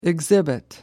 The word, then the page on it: exhibit: American English